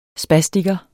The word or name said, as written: spastiker